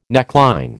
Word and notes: neckline: American English

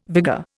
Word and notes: vigour: Indian

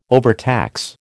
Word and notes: overtax: American English

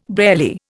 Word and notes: barely: Indian

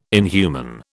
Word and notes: inhuman: British English

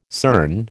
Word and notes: CERN: American English

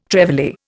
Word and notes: trevally: Indian

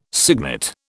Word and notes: cygnet: British English

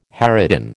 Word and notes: harridan: American English

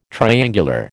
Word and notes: triangular: American English

